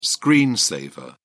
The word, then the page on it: screen saver: British English